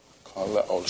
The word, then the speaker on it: kaleo: Kaleo, short E.